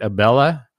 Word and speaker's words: Abela: Robert Abela? – Yes, correct. It's Abela. Yeah.